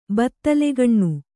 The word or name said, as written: ಬತ್ತಲೆಗಣ್ಣು